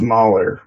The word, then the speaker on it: Mahler: How do you say his name again? – Mahler? – Gustav Mahler. Mahler. And that's Gustav, and then Mahler.